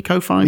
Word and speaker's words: Ko-fi: pronounce it Ko-fi? Ko-fi? – Yeah, yeah. I don't like the guys that do that.